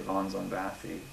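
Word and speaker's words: Von Szombathy: It depends, actually, on if you're going by the original Hungarian pronunciation or the English pronunciation. But I think that's actually pretty close to the Hungarian one. I just usually say Von Zombathy, but it's... You know, it kind of changes depending on who actually is doing it and who's spelling it.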